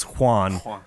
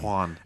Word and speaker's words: Juan: He wanted Juan to rhyme with ruin. – Juan. – And that involves getting rid of a fucking syllable, and it's a whole fucking Juan.